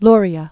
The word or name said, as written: Luria